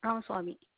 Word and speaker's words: Ramaswamy: Mohan Ramaswamy.